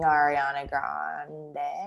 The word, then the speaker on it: Ariana Grande: And then I think like when I really started liking Ariana Grande.